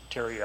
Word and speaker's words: Terry: Terry Eldridge, T-E-R-R-Y, E-L-D-R-I-D-G-E.